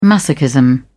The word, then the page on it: masochism: British English